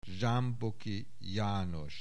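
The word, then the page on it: Zsámboky, János: Hungarian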